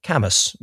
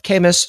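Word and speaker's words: Kamus: Camus, which I'm almost certainly mispronouncing. There's some people going to say it's called Camus. Some will say Camus. It's K-A-M-U-S, which out of the gate is already a better name than almost anything Amazon has ever given to anything.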